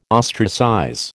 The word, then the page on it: ostracize: American English